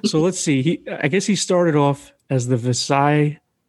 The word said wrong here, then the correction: Visalia: Ransacker. – Visalia. – Is that how you pronounce it? Okay. – Yeah. – I thought it was like some weird French word. – No, it's California.